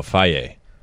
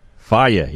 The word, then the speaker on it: Fage: This is, I think it's a yogurt company, F-A-G-E. – This one I know, Faye. – Faye, yes.